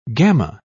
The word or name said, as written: gamma